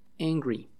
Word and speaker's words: angry: Se escribe angry, te lo voy a deletrear en español, A-N-G-R-Y, en inglés sería A-N-G. R-Y, angry. Entonces no se pronuncia angry, sino se pronuncia angry, como si la primera fuera una E, angry, ¿ok?